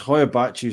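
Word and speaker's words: Hoia Baciu: You're here to talk about the hibachi forest. Right. um, did I pronounce that right? – It depends. It's it's Hoyabachi is like the English translation, um, but the word Hoia.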